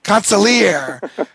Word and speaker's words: consigliere: I say concierge, but it's, consigliere to the boys of summer is what they called you in that article. – The G is silent.